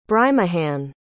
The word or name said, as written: Brijmohan